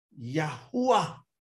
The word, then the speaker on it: Yahuah: Now, if you want to pronounce it from an English spelling, it's easily pronounced through the words, and you can type these in. If you're commenting, type these in. Y-A-H-U-A-H, Yahuwah.